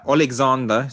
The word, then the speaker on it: Oleksandr: Yeah, Oleksandr. It's not even Alexander.